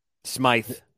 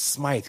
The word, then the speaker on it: Smythe: Is it the Conn Smith? – Smythe. – It's Smythe. God, see, this is how new I am to hockey. I'm like, I'm going to say Smith, and it's going to be fucking Smythe.